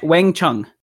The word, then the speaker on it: Wang Chung: Is it Wayne Chung? – Wang Ch- Wang Chung. Wang oh. Chun. Everybody Wang Chung. – Like W A N G.